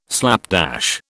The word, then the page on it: slapdash: British English